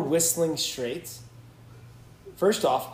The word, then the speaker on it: Whistling Straits: Like, whistling. First off, the T is silent.